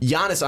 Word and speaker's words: Giannis: I believe you said the name wrong. It's Giannis. – That hard G. – Bunk fans are really going to be mad at you, Sean. – Giannis Antetokounmpo.